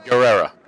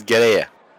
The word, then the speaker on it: Guerrera: Or as they'll say, Guerrera. But it is Guerrera. Hey, Joey Styles is actually saying it right. The way that it's supposed to be pronounced.